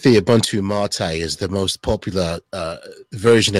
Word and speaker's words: MATE: But if you want to Ubuntu Mate, I'm not calling it Mate because it's spelled M-A-T-E, so it's Ubuntu Mate.